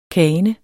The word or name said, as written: kane